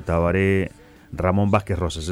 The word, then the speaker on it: Tabaré Ramón Vázquez Rosas: Bueno, aquí está sobre la mesa el discurso del presidente Tabaré Ramón Vázquez Rosas.